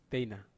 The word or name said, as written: teina